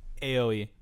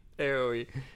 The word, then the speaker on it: Aoi: And he grabs the cup and refuses it to throw it in the first girl's face, who is Aoi, Ioi, or whatever her name is. How do you pronounce her name? – Aoi. – Aoi.